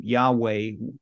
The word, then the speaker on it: Yahweh: Reference that's your reference to the writing out of uh, God's personal name Yahweh, uh, as we believe it's pronounced,